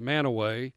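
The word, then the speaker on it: Mantua: And again, I will add to this list as time goes on, but Manaway, that's M-A-N as in Nancy, T-U-A, pronounced Manaway. Shalersville, you also have Ravenna, Ravenna Township, that's R-A-V as in Victor, E-N-N-A, Ravenna Township.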